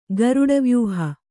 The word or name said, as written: ಗರುಡವ್ಯೂಹ